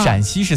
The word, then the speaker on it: 陕西: 他说因为陕西是一声，山西是一声，陕西是三声，用双写的 A 代表声调，所以是多了一个 A，叫做山安西。